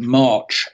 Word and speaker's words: March: And he was a guy called Juan Mar, spelled March, M-A-R-C-H, pronounced Juan Mar.